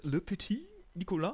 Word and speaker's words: Le Petit Nicolas: Der auf den Namen hört Le Petit Nicolas. Hab ich das richtig ausgesprochen? – Oui, Monsieur.